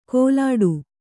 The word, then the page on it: ಕೋಲಾಡು: kōlāḍu